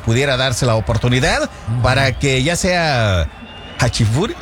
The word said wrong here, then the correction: Furuhashi: Furuhashi. – Ah, Diosito. Tengo que estarlo leyendo, y aparte de eso, no me sale la pronunciación. Furuhashi.